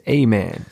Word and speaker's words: Aymann: My name is Aymann. Aymann, see, I'm a man.